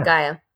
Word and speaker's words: Gaia: Gaia.